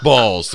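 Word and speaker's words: Baals: Is it pronounced Balls? – It's pronounced Balls. There's even a Harry Balls Drive. – I would say it's Balls. – It's H-A-R-R-Y, B-A-A-L-S, Harry Balls.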